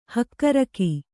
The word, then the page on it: ಹಕ್ಕಿರೋಗ: kannada